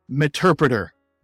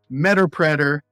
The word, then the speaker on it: Meterpreter: But the the the proper pronunciation is meterpreter. Now we'll hear people say meterpreter, metapreter. It's meterpreter.